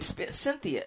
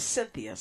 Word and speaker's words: Synthia: My name, Cynthia, is spelled S-Y-N-T-H-I-A, and my last name, McIntosh, is spelled M-C-I-N-T-O-S-H.